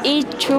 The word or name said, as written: Ichu